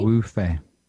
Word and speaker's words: Wu Fei: Who's joining us tonight, and then another artist, a Beijing artist named Wu Fei. Is that correct to say I'm pronouncing? – Yep. Yeah, Wu Fei.